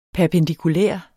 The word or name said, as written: perpendikulær